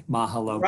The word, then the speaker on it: mahalo: Mahalo. Okay. Mahalo. Ma- so the accent's on the first syllable. – Uh, mahalo. Mahala. Yeah, on the ma.